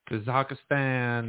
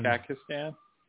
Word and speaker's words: Kazakhstan: Kazakhstan. – Kazakhstan. – Kazakhstan. Kazakhstan. – You just added extra syllables there.